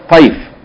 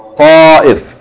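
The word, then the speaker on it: Ta'if (طائف): It's not pronounced Ta'if. It's pronounced what? Ta'if. At-ta'if.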